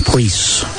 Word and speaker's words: Pries: The Priest girls spell their name P-R-I-E-S, pronounced Priest.